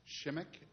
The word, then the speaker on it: Shimek: My last name is spelled, so my name is Steve Shimek, S-H-I-M-E-K.